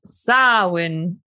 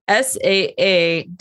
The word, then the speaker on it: Samhain: No, no, no. It's spelled like Samhain, but it's Samhain. Samhain. – Oh. Samhain. All right. So Samhain. – I apologize if that's wrong. I did look up the pronunciation. I wrote down Saa-WN because that's what they had on the website that I looked up.